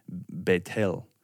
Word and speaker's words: Bethel: Though it is kind of funny, when I was in Israel, I was walking with our guide, and she had said Bethel mm-hmm. is how you say it.